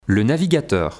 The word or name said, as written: le navigateur